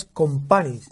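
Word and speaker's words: Kompany: Te voy a rectificar, has dicho dos veces Campani y es Compani. – Compani.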